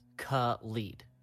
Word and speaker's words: Khalid: Apparently he tweeted and he said, Khalid, quote, is pronounced Khalid. So it is Khalid. – Khalid. Okay. Mm-hmm. All right. Yeah, I need to get that through my head. Khalid. And then DJ Khaled is Khaled.